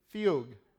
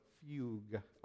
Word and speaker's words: fugue: Fugue. Does anybody know what a fugue is? Yeah. I saw that. Yeah, that's right. I saw that. So in the parentheses, it said F-E-W-G for pronunciation. So fugue.